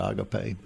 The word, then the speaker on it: agape: Pronounce it agape.